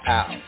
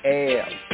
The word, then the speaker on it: El: Some of the Hebrews like to pronounce it as Al, you know, and this is to the point of like illusion, right? And there could be a word allusion, but here the proper pointing is El, because we study the more ancient Hebrew and Hebraic, we find Ayaleh.